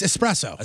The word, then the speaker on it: espresso: It's espresso. – Espresso or expresso. – There's no X in especially. Can we get that out there? – Espresso is the correct way to say it.